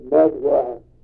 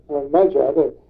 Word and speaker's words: Magyar: And if you see it on the maps or other old stuff, it looks like it's spelled Magyar, but it's Magyar.